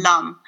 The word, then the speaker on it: lam: So we have like lam or lum. It's the Sanskrit's more like it's more like you pronounce it like thumb. It's more like lum.